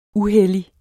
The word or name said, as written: uhellig